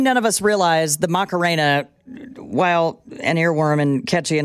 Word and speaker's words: Macarena: Macarena. Macarena. I'm going to have that in my head all day long. – You don't have to roll your R in that Macarena. – Word.